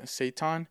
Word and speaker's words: seitan: Now, something I recently found out about is something called seitan. It's spelled S-E-I-T-A-N. I know it sounds like you're saying the devil Satan, S-A-T-A-N.